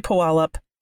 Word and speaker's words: Puyallup: I've always heard Puyallup. – Puyallup. Okay. That sounds logical. Okay, so we're going to say Puyallup. If I'm butchering it, y'all can let me know.